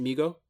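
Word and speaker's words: Mi-Go: Until a passing Migo, is that how you pronounce it, Migo? – Yes.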